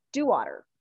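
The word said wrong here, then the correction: Dauwalter: DeWalter, yeah.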